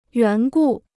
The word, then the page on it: 缘故: yuán gù